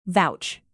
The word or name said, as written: vouch